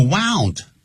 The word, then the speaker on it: wound: 这个 wound 是他的过去时及过去分词念成 wound 如果念成 wound 代表伤口